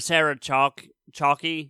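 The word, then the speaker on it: Sarah Chalke: where Sarah Chalky is how you say her name,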